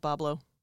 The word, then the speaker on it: Bois Blanc: it is on Bois Blanc Island. – No, it's still pronounced Boblo. – Is it still pronounced Boblo? – Yeah. – Okay. – That's why. – What the fuck? – That's pronounced Boblo. That's where the theme park got its name. – Yes. – They just spelled it phonetically, like Chef Boyardee.